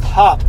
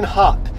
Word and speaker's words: Happ: Ian Happ, who I pronounced Hop for some reason. I don't know why I did. It really was annoying when I was listening to myself again. Ian Happ.